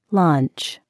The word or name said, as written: lunch